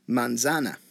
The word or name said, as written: manzana